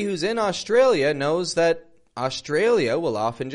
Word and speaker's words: Australia: And by the way, notice the contrast in the syllable stress there. How the tray goes strong. Australia. Play around with that yourself. Australia.